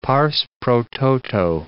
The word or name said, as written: pars pro toto